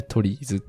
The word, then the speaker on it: TRIZ: うのは、共通のやり方があるに違いないと思って、そのやり方をね、体系化しようということで、このトリーズという考え方は生まれました。トリーズっていうのはあの、 TRIZ。これでトリーズって読むらしいです。